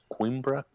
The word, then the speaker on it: Coimbra: Yeah, I got a very lazy American tongue, and I want to say Coimbra, but I believe the correct pronunciation is Coimbra. Coimbra. – Okay. – More of like a Q, I was gonna say, Coimbra.